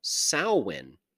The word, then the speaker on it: Samhain: It's usually spelled, however, a little differently than it's pronounced. It's spelled, if you're looking at it in English, Samhain, so Samhain. However, it's a Gaelic word. It's pronounced Samhain.